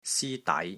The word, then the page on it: SI1 DAI6: Cantonese